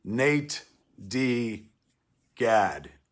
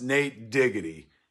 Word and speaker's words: natedigad: It's pronounced Nate Diggity in your ears. Enjoy the listen. – Welcome to an all-new episode of It's Pronounced Nate Diggity. I am your host, Nate Diggity.